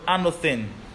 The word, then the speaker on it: anothen: It now says the word there's again is the word anothen.